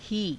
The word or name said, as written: hii